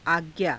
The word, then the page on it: aajcnaa: Hindi